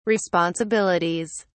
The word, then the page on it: responsibilities: British English